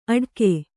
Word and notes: ಅಡಕೆ: Kannada

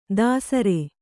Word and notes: ದಸರೆ: dasare

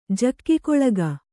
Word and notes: ಜಗ್ಗಲಿಗೆ: jaggalige

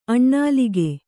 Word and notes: ಅನಿಲಕ: Kannada

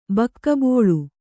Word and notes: ಬಕ್ಕಬೋಳು: Kannada

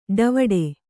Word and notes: ಡವುಡೆ: Kannada

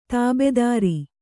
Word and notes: ತಾಬೆದಾರಿ: kannada

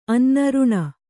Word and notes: ರೊಣೆ: roṇe

